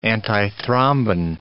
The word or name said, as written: antithrombin